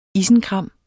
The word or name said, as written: isenkram